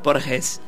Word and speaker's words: Borges: Borges.